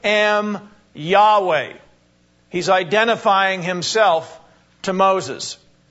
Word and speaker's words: Yahweh: It's because that word is being translated from the Hebrew word, which is spelt Y-H-W-H. And we believe that it's pronounced Yahweh. Yahweh is the proper name of Almighty God.